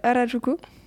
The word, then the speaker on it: Harajuku: Euh, euh, Harajuku.